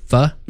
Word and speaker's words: pho: Is it F-A? – Pho? No, it's actually P-H-O. – Oh, pho? – But it's pho. It's pronounced pho.